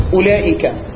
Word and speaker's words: أولئك: يعني واحد بيقول لي مثلا اولئك، انا ما نطقتش الواو دي، اولى اولى، اولات، انا ما نطقتش الواو بس كتبتها.